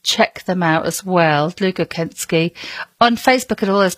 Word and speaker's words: Dlugokecki: D-L-U-G-O-K-E-C-K-I. But it is pronounced Deluga Kensky. I can assure you.